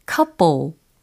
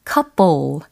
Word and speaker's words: couple: 영어 발음은 couple, couple, couple 이렇게 연습해 두시면 돼요. 커플 이게 아니고 커플도 아니고 couple, couple, couple, 뽀, 뽀. 예, 뒷부분 소리에 좀 더 집중을 해주시면 좋을 것 같고요.